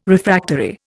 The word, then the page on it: Refractory: Indian